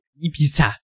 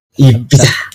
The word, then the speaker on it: Ibiza: Ähm, wir starten mit der Ibis, Ibiza. Ha. Ibiza.